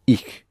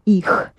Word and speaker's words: их: Pronounce it hard. Х. Их. – Их. – Да, их.